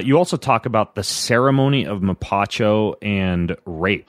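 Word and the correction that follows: hapé: Hape. – Hape. – I knew you were going to call it rape. I just knew that. – You actually say in the book it's pronounced hape, but I just wanted to put you on the spot.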